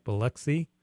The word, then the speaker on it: Biloxi: Rhonda, did I say that right? Biloxi? – Yes.